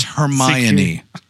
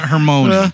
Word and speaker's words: Hermione: It's Hermione. – Hermione? – Hermione. Yeah, right. Not Hermione. – When he pushed that. – Hermione.